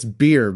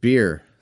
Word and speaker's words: bier: I think it's beer, but I've never liked this pronunciation. Hold on, let me check. All right, yeah, it's just beer.